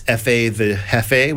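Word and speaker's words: Fatha Jefe: The primary vocalists that you hear are the artists F.A. the Hefe, which is spelled F.A.T.H.A. space J-E-F-E, and B.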